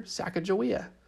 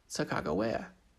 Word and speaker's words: Sacagawea: Now, the reason some people call her Sacagawea and other people call her Sacagawea is because when her name was first written down, no one really knew how to say her name when they read it. So they guessed and called her Sacagawea. But in her own language, her name was Sacagawea.